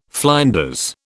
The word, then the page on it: flinders: British English